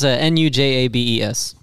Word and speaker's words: Nujabes: Nijibus. – Oh, Nijibus. It's Nujabes.